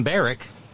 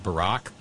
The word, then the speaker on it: Barrack: I've read several of these stories, and every time I see his last name, I want to pronounce it Barack. No, this is Tom Barrack.